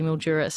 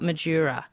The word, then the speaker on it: Mildura: And Grant in Wentworth, a lot of people messaging that it's Mildura, not Madura.